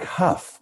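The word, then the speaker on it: Hough: You were partnered with Derek Howe. Is that what you say his name? Huff? – Derek. – See, this is how out of touch I am with like contemporary anything. Derek Huff.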